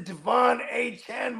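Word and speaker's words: De'Von Achane: Because when everyone was saying his name wrong, he was balling. He's like, listen, man, none of that have arrived. You pronounce it Devon A-chan, motherfuckers.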